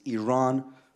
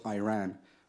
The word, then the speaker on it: Iran: You say you support the Iranian people, but you're pronouncing our country's name wrong. Our country is named Iran, not Iran.